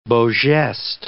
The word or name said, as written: beau geste